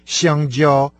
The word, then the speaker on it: 香蕉: Впрочем, до этого момента мы с вами тоже не знали, что банан по-китайски «сяндзяо». Оба слога произносятся с первым тоном.